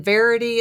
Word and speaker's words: Varady: And Dr. Kristen Verity, is that how that's pronounced? V-A-R-A-D-Y, the every other day diet.